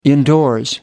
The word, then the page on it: indoors: American English